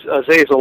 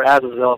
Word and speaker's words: Azazel: is Azazel or Azazel, depending on how you pronounce it.